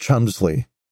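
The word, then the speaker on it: Cholmondeley: Her last name, which is spelled in a quite complicated way, is pronounced Chumsley.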